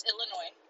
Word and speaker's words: Illinois: I'm not sure if I'm supposed to pronounce the S in Illinois. – No, it's Illinois. – Oh, okay. – It's Illinois, not Illinois.